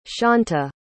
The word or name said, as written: shaunta